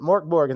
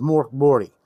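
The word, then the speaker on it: Mörk Borg: So we'll call it Morkborg because a lot of people know, but Moriborty, if I'm pronouncing that right, I may still be butchering it. The G is supposed to be more of a Y.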